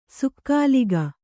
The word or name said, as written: ಸಕಾಲಿಕ